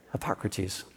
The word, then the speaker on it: Hippocrates: So Hippocrates? Hippocrat? Yeah? Got it? Okay. Thank you very much, Rachel. Hippocrates.